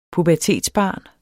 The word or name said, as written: pubertetsbarn